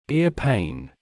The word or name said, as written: ear pain